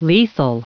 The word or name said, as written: lethal